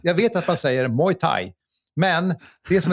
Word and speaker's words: Muay Thai: Min fråga, när, när ska lära sig uttala, och då vet jag vad han syftar på, att jag säger Moa Thai. Eh, jag har hört många olika uttal. Inget kan kallas korrekt. Tänk bara, tänk bara Muay Thai, så är man ganska rätt ute.